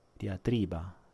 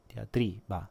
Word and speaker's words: diatriba: Uh, eh, 1 ora e 14, ragazzi, stiamo per arrivare alla fine diatri, diatriba. Eh, Pablo, anch'io dico diatriba, dia, diatriba, sì, esatto. Però sembra che in italiano si dica, si dica in un'altra maniera, boh, diatriba, con l'accento fonico sulla seconda, ok, diatriba, diatriba, sì, ok.